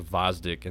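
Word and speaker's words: Gvozdyk: I'm pretty sure it's Vozdik and the G is silent.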